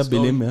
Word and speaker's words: Belém: Das ist ja in Belém, wie spricht man das aus? Irgendeine Stadt in Brasilien? – Ja, Belém, ja.